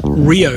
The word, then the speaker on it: REO: REO Speedwagon got their name from a manufacturer of trucks, pronounced Rio Speedwagons, and they were often used for fire trucks.